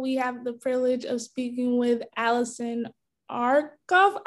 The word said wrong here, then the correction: Arnoff: I may have mispronounced your last name. Thank you for joining me today, Allison. – Glad to be here. Thanks for having me. And it's Arnoff. – Arnoff. My apologies for mispronouncing it.